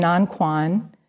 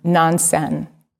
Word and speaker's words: Nanquan: So this is Zhaozhou and Nanquan. You might recognize their Japanese pronunciation of their names, Joshu, right? As in Joshu's Mu, and Nansen, like Nansen kills the cat.